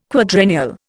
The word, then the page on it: quadrennial: Indian